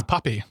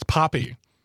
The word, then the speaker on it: Papi: P-A-P-I, which means father or dad in Spanish. – Poppy. It's not Pappy. It's Poppy. It's Poppy.